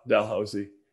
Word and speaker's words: Dalhousie: Dal, Dalhousie.